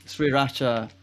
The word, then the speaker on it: sriracha: The sweet sriracha.